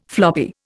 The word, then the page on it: floppy: Indian